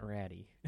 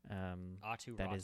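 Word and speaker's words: Aatu Räty: They gave up Anthony Beauvillier, and I'm going to murder this, but I think it's Etu Ratu, I think, is the closest I can get in his pronunciation for his name. It's not Ratty. It's not like Ty Ratty.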